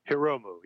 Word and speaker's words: Hiromu: John Hiramohu or Hir- Hiramu Takahashi? Is that how you say it? Hiromu, yeah.